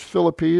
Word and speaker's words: Philippi: To the church in Philippi, that's how the natives pronounce it. You'll hear it pronounced Philippi here in the States, but Philippi is its city name.